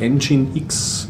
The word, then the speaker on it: nginx: Der nennt sich Engine X. Über den stolpert man jetzt man öfters. Da schreibt man N, also N-Gigs schreiben.